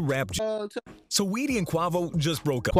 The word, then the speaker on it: Quavo: Yes, it's Quavo.